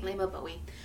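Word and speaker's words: Leymah Gbowee: For anyone trying to find her, her last name is actually spelled with a G, so it's Layma, L-A-Y-M-E-H-G-B-O-W-E-E. – I see. – Layma Bowie.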